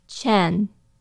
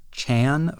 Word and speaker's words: Chen: I had noticed that in the movie, Amuro says it more like Chen. – On my part, just out of force of habit, based on the way it's spelled in English, I have been calling this character Chan for a really long time.